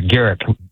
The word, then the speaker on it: Garrick: That's a Garrick with a hard G.